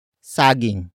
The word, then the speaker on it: saging: And that is sagging. Sagging. Sagging. Sagging is spelled as S-A-G-I-N-G.